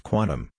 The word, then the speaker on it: Qtum: Qtum, pronounced Quantum, is a Singapore-based blockchain technology that bridges Ethereum smart contracts on top of Bitcoin's stable blockchain while using proof-of-stake for verification.